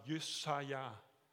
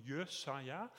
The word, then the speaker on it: Jesaja: Jesaja, ein wunderbarer Name. Man könnte auch sagen Jesaja. Also die Hebräer sagen immer Endbetonung Jesaja äh, heißt Gott, ähm, der Herr ist meine Rettung, der Herr ist Heil, der Herr hilft.